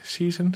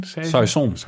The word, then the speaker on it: saison: Ik heb hier een ja, hoe spreek je het uit? Season? – Saison. Saison. Ja, jij, jij, jij komt natuurlijk naar Frankrijk, hè?